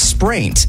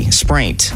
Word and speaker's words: spraint: What is spraint? S-P-R-A-I-N-T. Spraint.